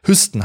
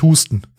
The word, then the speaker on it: Hüsten: Hüsten heißt das, nicht Hüsten. Und auch nicht Husten.